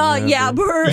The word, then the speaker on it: yabber: Yabber? – Cats, keys. – Do you mean blabber or jabber? – No, yabber. – Yabber. Jabber with the soft J.